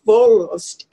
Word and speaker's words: Wollust: For example, the word Wollust, and in German, W is pronounced as if it were a V, is the same as the word voluptuous, which of course has a lot of connotations or judgments with it.